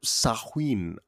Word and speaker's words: Samhain: It is spelled S-A-M-H-A-I-N, but it is pronounced Samhain.